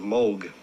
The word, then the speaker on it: Moog: In Great Britain, English-speaking countries, people see the double O and they say Moog. I just happen to like the sound of Moog better than Moog.